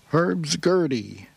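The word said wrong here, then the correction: Hurdy Gurdy Glissando: Did I say that right? No, Herdy-Gurdy Glissando, all 8 minutes and 54 seconds of it right here on Raised on Rock.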